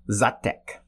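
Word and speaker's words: Žatec: By the way, they don't call it Satz hops in the Czech Republic. – And what is it called? – Zatek. They probably pronounce it differently, but they're called Zatek hops.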